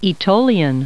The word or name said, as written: aetolian